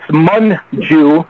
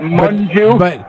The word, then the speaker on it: Munju: Your southern drawl, you're pronouncing her name incorrectly. It's Munju. I've heard her say that, Munju.